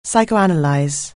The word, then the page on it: psychoanalyse: anglais